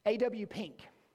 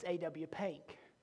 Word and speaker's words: A.W. Pink: A.W. Pink. That's how you pronounce his name if you're from the north. If you're from the south, it's A.W. Pink.